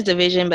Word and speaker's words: DVSN: It is division, but I pronounce it DVSN.